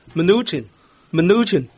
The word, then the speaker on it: Mnuchin: 嗰、那個讀音呢如果聽鬼佬讀啊即係呢個睇呢個 Charlie Rose 嘅節目訪問嗰個呢就係、是、咁樣讀嘅。 Mnuchin。